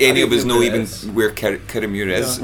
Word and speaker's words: Kirriemuir: Do you say Kirriemuir, or how do you pronounce Kirriemuir. – It? – Kirriemuir.